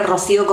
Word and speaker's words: Rocío: que se escribe Rocío con C y Luz con Z,